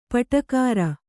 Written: ಪಾಡುಗಾರ

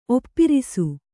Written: ಒಪ್ಪಿರಿಸು

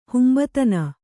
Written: ಹುಂಬತನ

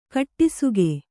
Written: ಕಟ್ಟಿಸುಗೆ

